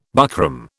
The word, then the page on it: buckram: British English